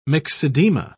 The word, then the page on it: myxedema: British English